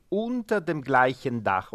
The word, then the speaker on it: unter dem gleichen Dach: ma si riflette sul fatto che siamo tutti unter dem gleichen Dach.